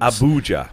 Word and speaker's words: Abuja: Abuja.